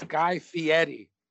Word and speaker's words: Fieri: Say his name again. – Guy Fieri. – Fieri. – It's Guy Fieri.